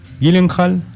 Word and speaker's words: Gyllenhaal: Gyllenhaal, Jackman, Paul Dano.